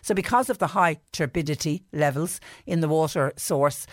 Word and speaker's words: turbidity: Turbidity.